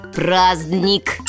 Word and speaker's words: праздник: Согласная Д. – Праздник.